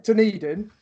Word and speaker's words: Dunedin: how do you pronounce that town? Dunedin, is it? Dunedin? Dunedin, that's right. Dunedin.